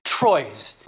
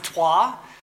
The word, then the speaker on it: Troyes: Now, everyone, and I'm going to apologize because I'm going to pronounce it as Troyes. But if anyone here is French, I've just insulted you because apparently it's pronounced Trois, but I'm not going to call it that.